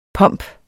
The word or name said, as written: pomp